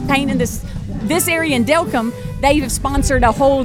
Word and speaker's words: Delcambre: And so they added a day just for Delcambre.